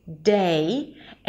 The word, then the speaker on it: day: To say the word day, you wanna start with a D sound and then it's the A as in day. So if you don't have the A diphthong in your language, you're probably pronouncing it as day, day. You have to reach an E sound at the end, day, and see that there is transition from one sound to another.